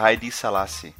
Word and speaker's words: Haile Selassie: É, o, pelo menos eu, eu tenho ouvido muito a pronúncia Haile Selassie.